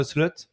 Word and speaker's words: Slot: très prometteur qui s'appelle Arne Slot. Je le prononce bien C'est ça. Nickel. J'ai compris, les O, tu fais des E c'est nickel.